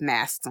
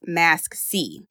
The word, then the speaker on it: MaskC: masks on hand. So I like this company. I thought it was pronounced mask, but it's pronounced MaskC.